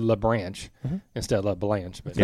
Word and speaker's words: LaBranche: We know Jenny, but it's LaBranche. – LaBranche. – LaBranche, right? Am I saying that right? It's got an E. It's branch with an E and an LA in the beginning, if that makes sense.